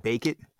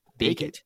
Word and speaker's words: Bacot: I think Bacot. – Bacot. – Armando like bacon, but Bacot. Oh, Bacot, as in like put this in the oven and bake it.